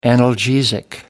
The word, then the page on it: analgesic: American English